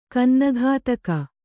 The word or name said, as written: ಗನ್ನಗತಕ